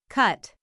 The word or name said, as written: cut